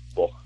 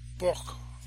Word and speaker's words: Bokh: How do you, now, in the current book, give me the correct pronunciation on it, because I'll just say B-O-K-H. Boch. – Boch, okay. – Yeah. – So it's almost like a Germanic, the last two letters, Boch? – Yeah, kind of, yeah.